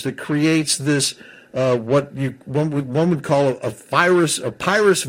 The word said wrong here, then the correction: Pyrrhic: victory. I'm probably mispronouncing it. Do you know what I'm talking about? – Pyrrhic. – Pyrrhus? – Pyrrhic. – Pyrrhic, thank you.